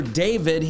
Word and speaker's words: D4VD: The singer David, he's got a new 5-song EP out today. His stage name is D4VD, and it's pronounced David.